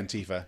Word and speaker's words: Antifa: Am I saying it right? – Antifa. Yeah.